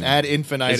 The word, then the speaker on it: ad infinitum: Is that a word? That's a word. – Infinitum. – Infinitum. Mm-hmm. Ad infinitum. – It's the way it's normally pronounced, but, you know, it's Latin, so who the hell knows for sure.